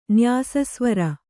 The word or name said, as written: ನ್ಯಾಸಸ್ವರ